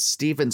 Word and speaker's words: Stephen: If we're going as to where we're named after, my name is Stephen, spelt with a PH, because after four sons, my mom badly wanted a girl.